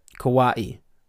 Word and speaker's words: kawaii: Kawaii. Pronounced kawaii, like I pronounced it.